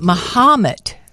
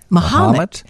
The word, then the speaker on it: Mahomet: Mahomet. – Mahomet. – It's spelled Ma, then H-A-H, Ha, M-E-T, Mahomet. Boy, I bet that's confusing, because it sounds a little like Mohammed. Mahomet.